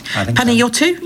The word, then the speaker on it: Panayiotou: Yorgos Kyriakos Panayiotou. I think that's how you say it. Panayiotou, yes. But he was English.